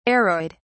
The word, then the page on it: aroid: British English